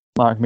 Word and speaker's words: Marek: Marek, I think that's how you spell it, M-A-R-E-K. – Mark. It's Mark. I know. – Is it Mark? – Yeah. – Is it Mark? Oh, what the hell? Who the hell has an E to Mark?